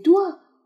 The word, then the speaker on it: doigts: 好，接下来，des doigts courent dans l'aiguille sur le clavier du piano。好了，de do，请注意这个单词的发音，最后 j de 都是不发音的，s 是复数加上去的，也不发音，它是手指的意思。